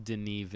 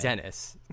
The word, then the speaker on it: Denis: Denis Villeneuve. Okay. But it's anyway. not Dennis. no, it's not Dennis Villanueva.